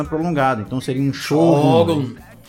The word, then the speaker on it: Shogun: E sobre o nome ser um SH ou X, acho que o pior de tudo é o acento no O, Shogun. Que é foda. – Shogun? – É, Shogun. Que realmente se escreve Shogun com acento, né? E ele disse que isso vem porque no SH, no, na palavra original, o O tem aquele tracinho em cima.